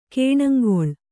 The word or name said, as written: ಗುಣಂಗೊಳ್